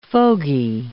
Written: fogey

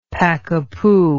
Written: pakapoo

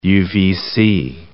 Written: UVC

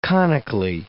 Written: conically

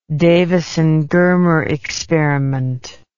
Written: davisson germer experiment

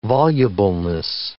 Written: volubleness

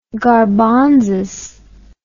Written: garbonzas

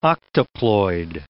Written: octoploid

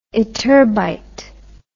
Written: ytterbite

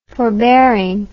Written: forbearingly

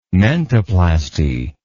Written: mentoplasty

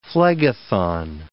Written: Phlegethon

